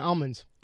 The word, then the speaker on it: almonds: California almonds. – Almonds. That's correct. It's almonds. – He said an L. – He didn't say an L.